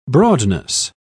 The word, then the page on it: broadness: British English